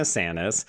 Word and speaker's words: Tsanis: It's like the harsh T or the quiet T, but I'm going to just default and say it's a silent T. – Yes. – So we'll say Joanna Sanis.